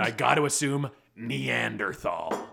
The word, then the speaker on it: Neanderthal: It's how you pronounce it. You pronounce Neanderthal.